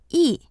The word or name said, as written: yi4